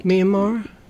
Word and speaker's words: Myanmar: Sung and UK, they actually escaped extreme poverty from Myanmar. Am I saying that right? – Myanmar.